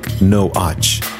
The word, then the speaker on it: Noach: The Hebrew pronunciation of Noah sounds more like Noach.